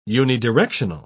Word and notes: unidirectional: British English

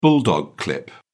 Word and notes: bulldog clip: British English

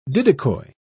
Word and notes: didicoi: British English

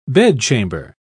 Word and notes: bedchamber: British English

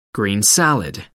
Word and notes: green salad: American English

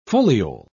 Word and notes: foliole: British English

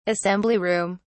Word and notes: assembly room: British English

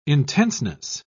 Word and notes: intenseness: British English

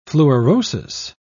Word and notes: fluorosis: British English

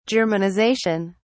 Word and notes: germanisation: British English

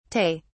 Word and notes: tay: British English

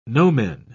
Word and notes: nomen: British English

